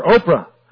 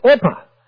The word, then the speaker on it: Orpah: But some of the family did not know how to pronounce the name, and they called her Oprah. But really, her name's Orpah.